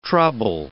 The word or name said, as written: trouble